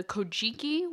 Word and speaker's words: Kojiki: So the main stories about Toyotama Hime are told in the Kojiki, which is the Record of Ancient Matters, and the Nihon Shoki, which is known as the Chronicles of Japan. – Super proud of your pronunciation there.